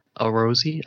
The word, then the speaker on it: Arozzi: Gaming chair via Arozzi, I think. That's how you pronounce it. – Maybe it's Arozzi, like pizza? Is it yeah, Italian? Is it Italian made? If it's Italian made, it'd be Arozzi.